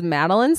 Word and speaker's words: Madelein: And my name is Madelein Smith.